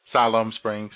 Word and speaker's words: Siloam Springs: Siloam Springs. Am I pronouncing that right? – Siloam Springs, yeah.